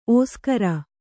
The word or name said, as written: ಓಸುಗರ